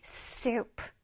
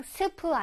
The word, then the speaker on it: soup: soup. soup. 그냥 스프 아니랬어요. 스 소리 내줘야 되죠? soup. soup. 스한 다음에 우 소리를 내줘야 돼.